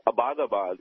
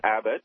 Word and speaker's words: Abbottabad: And yes, I'm from Pakistan, and I've been hearing mispronunciations of Abbottabad. It's not that at all. It's actually Abbott, as in the British name Abbott.